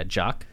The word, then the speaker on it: Jacques: Is it, is it Jock? – You know, J A C Q U E S. – Yeah, Jock.